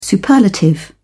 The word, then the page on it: superlative: British English